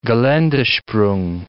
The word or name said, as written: gelandesprung